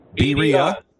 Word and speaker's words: birria: Birria.